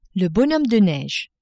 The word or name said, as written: bonhomme de neige